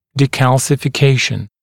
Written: decalcification

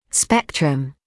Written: spectrum

